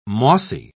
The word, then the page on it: mossy: British English